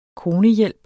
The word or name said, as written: konehjælp